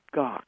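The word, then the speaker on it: Gach: It's Gok, which, let me spell it for you. It's just like the composer Bach, but it's got a G on it. So it's G-A-C-H. And so I'm Michael Gok.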